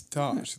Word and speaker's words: Daash: It's Dosh. It's not Dash.